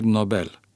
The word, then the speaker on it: Nobel: Dar celebrul industriaș Alfred Nobel a fost suedez, nu german.